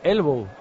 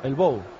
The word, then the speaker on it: Elbow: Hay que pronunciar bien, vaya, el grupo era Elbow, no Elbow. De verdad. Dale, Bautista. – Bueno, pues hay un jugador del Villarreal. – E-L-B-O-W.